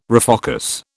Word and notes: refocus: British English

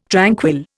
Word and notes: tranquil: Indian

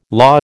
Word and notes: law: American English

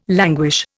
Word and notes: languish: Indian

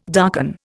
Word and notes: darken: Indian

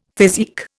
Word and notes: physique: Indian

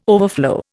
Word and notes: overflow: Indian

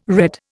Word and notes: red: Indian

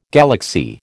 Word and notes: galaxy: American English

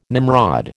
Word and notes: Nimrod: American English

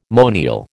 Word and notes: monial: American English

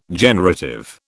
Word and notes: generative: British English

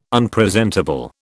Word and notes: Unpresentable: British English

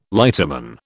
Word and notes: lighterman: British English

